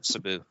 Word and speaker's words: Sabu: Maybe Darby's the new Sabu. Oh, sorry, Sabu. – Sabu. – You have to say it like Sean. Sabu.